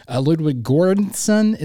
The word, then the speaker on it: Göransson: But Ludwig Göransson is how you pronounce his name.